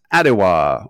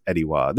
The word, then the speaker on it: Atiwa: Next, we move on to Ediwa, or as you might think it's pronounced, Adiwa or Atiwa. But I think, and I'm pretty sure I've done some work looking into it, it is pronounced Ediwa.